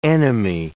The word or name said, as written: enemy